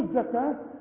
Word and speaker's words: الزكاة: هنا وأقيموا الصلاة وآتوا الزكاة، الواو بلغيها، أقول وآتوا الزكاة بتلغى الواو لأنها ساكنة عند الوصل، إنما تثبت إذا وقفنا نقول وآتوا، إنما عند الوصل بتتلغي، أقول وآتوا الزكاة، وكلمة الزكاة والصلاة والربا وهكذا بتكتب بالواو وتنطق بالألف.